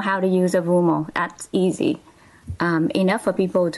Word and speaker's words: Avumo: Avumo is spelled A-V-U-M-O.